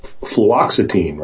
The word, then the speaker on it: fluoxetine: John explains how it was that, well, it's name is fluoxetine, right? Is that how you say it, Deanna? – Right.